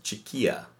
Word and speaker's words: Chiquilla: She rescued us, and it is actually, the word is pronounced Chiquilla. So it is Commando Chiquilla.